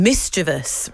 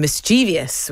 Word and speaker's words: mischievous: And mischievous rather than mischievous, which is where often people go wrong.